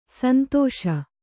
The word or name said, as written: ಸಂದೇಶ